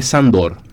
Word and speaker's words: Sándor: que a pesar de que tiene esa tilde allí, se pronuncia Sándor.